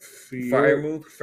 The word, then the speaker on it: Freiermuth: Pat Freiermuth.